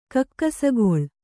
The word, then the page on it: ಕೆಕ್ಕಸಗೆಲೆ: Kannada; kekkasagele